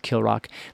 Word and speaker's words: Kilravock: But the 16th Laird, who is now in the chief during the Roses of Kilrock, it's spelled Kilravock, but it's pronounced Kilrock.